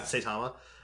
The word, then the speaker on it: Saitama: is it Satami? Is that how you say his name? Satama? – Saitama. Yeah. – Saitama.